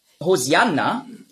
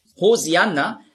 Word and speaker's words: Hosianna: Hosianna ist einfach die griechische Aussprache von Hoshi'anna. Hosianna, das ist die griechische Aussprache. Weil die Griechen, die haben im Alphabet kein Sch-Laut. Nur Z. Die Hebräer schon. Auf Hebräisch sagen Hoshi'anna. Aber Griechen können das nicht.